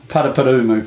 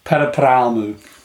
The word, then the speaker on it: Paraparaumu: Paraparaumu. – Thank you. Hang on, we'll do it again. – Paraparaumu. – Paraparaumu.